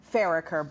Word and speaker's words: Farricker: Farricker, but...